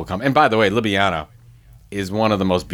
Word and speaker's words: Ljubljana: Most people don't pronounce it Ljubljana either. So well, it's Ljubljana. There's an L in there, just FYI. – The L is silent, man.